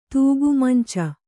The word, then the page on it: ತೂಗುಮಂಚ: tūgu manca